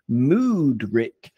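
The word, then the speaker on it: Mudryk: Mudrick, for example, or so we call him Shitrick, or if we're going to use the Ukrainian pronunciation, it would be Moodrick, in which case we should call him Bad Mood Rick, because he never looks happy.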